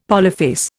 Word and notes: polyphase: Indian

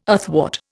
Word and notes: athwart: Indian

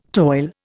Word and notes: toil: Indian

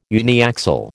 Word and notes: uniaxial: American English

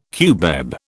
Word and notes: cubeb: British English

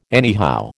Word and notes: anyhow: American English

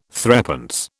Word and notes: threepence: British English

